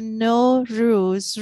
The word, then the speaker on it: Nowruz: Well, no, in our language, Persian means new. So basically, when we say no ruz, ruz means day.